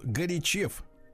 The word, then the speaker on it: Горячев: В скобках ударение на букву Е, а не на Ё. То есть, получается, он Горячев. Угу.